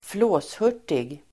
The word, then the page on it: flåshurtig: [²fl'å:shur_t:ig]